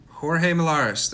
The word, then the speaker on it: Jorge Millares: But today we are joined by Jorge Millares. Did I say it right? – You I gotta, said it right.